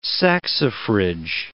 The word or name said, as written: saxifrage